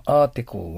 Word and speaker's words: article: この article は、まず L の発音をしっかり出す。アートは誰でも出せます。 article、LLL です。article。こういう歯切れが大事ですね。L の発音。